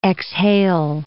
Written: exhale

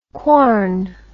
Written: quorn

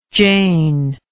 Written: jane

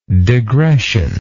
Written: degression

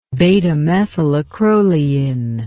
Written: beta-methylacrolein

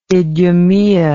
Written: idumaea

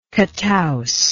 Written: catouse

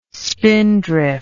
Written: spindrift

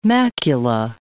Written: macula

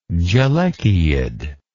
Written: gelechiid

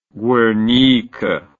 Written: Guernica

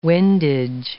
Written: windage

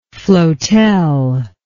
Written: floatel

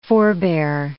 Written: forebear

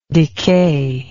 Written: decayer

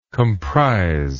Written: comprisal

